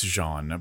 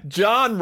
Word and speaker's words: Jean: Jean, I know it's Jean, but I'm going to American my way through these French pronunciations today. Bear with me. How often? – John Rollin? – Jean Rollin.